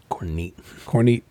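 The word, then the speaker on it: CorNeat: So the other one was, it actually goes out to an Israeli company, Cornet. It's spelled C-O-R-N, like Nancy, E-A-T. – Cornet.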